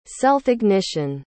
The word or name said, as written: self-ignition